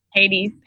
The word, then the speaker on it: Hades: But when Odysseus decides to leave Circe and so to abandon immortality, is sent to, wait, now I pronounce it in the Italian way, Hades. How'd you say it in English? Hades? – Hades. – Hades. Okay. Ade.